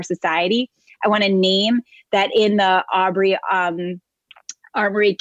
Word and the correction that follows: Ahmaud Arbery: case. I'm sorry, I said that wrong. In Ahmaud Arbery case,